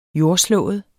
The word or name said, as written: jordslået